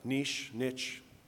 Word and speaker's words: niche: Through my career as a builder, I searched for I never know how to say this, but I looked it up. Niche, it's all good.